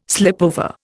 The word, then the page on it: slipover: Indian